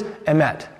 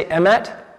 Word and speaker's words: emet: The Hebrew is emet. Can we all say emet? You could spell it E-M-E-T.